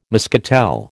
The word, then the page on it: muscatel: American English